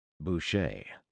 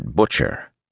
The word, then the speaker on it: Boucher: Boucher. Most people, seeing before hearing it, mispronounced it Butcher.